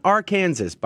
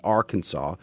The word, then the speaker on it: Arkansas: Mississippi has the highest percentage of babies born to unmarried moms, followed by Louisiana, New Mexico, Nevada, Alabama, Delaware, Florida, Arkansas, not Arkansas, by the way. I think they call themselves Arkansans, but the state is pronounced Arkansas. West Virginia, South Carolina, worst offenders of the most amount of unmarried moms, unfortunately.